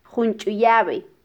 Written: junchuyabe

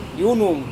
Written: yunung

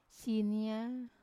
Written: sinia

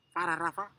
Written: fararafa

